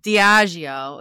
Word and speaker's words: Diageo: Diageo.